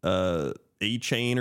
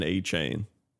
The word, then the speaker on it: Achane: People were saying Devin Achane or something, but he said it's pronounced Devin Achane.